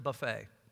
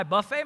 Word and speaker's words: buffet: Now, in a Daniel fast, do not pronounce it buffet. It's hard, isn't it? So your mind plays tricks on you. I buffet my body.